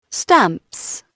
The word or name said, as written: stamps